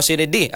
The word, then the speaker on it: sine die: Okay, si Senator Cayetano daw nagdeklara sang sinidai, tama? Adjournment. Sinidi ang pagpronounce ani judge, no?